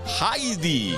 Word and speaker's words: Heidi: Heidi, vaya desilusión, resulta que se dice así, Heidi.